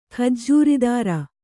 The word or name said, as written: ಖಜ್ಜೂರಿದಾರ